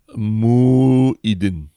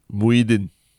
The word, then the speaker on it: Muhyiddin: He basically told okay, first of all, how do you pronounce the Malaysian PM's name? – Muhyiddin. Correct. – Why the break? There's no hyphen or anything. Can you just say it? – Muhyiddin. – Yes, correct. Yes.